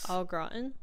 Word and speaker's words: au gratin: You take, I can't pronounce it, it's like al, it's A-U grant. – Au gratin? – Au gratin gravy.